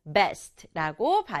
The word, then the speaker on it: best: 하지만 영어는 한 박자로 best라고 발음해요.